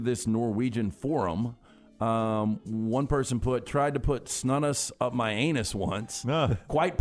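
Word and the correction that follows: snus: Chris says it's pronounced snooze. – All right. – That's the way they say it over there.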